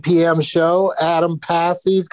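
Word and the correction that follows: Pasi: going to, am I saying it right? Passy? – It's Posse. Yeah, Adam Posse. Yeah. He's... – Oh, God. I wish he spelled it P-O-S-S-E, but it's P-A-S-I.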